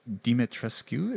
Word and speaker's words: Dimitrescu: And there's obviously these creatures out here that kind of lead him to Castle Dimitrescu.